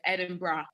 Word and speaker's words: Edinburgh: So Americans call it Edinburgh, but it's Edinburgh.